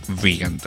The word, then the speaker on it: Wiegand: Es de hecho tecladista de la agrupación Toy y de la agrupación Diorama, el, eh, el artista que está detrás del proyecto Vigand, o es que se escribe Vigand con W-I-E-G-A-N-D, por aquello de que quieran buscar en Bandcamp si fue que les gustó este tema llamado Get Informed en su video version.